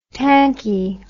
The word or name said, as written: tanky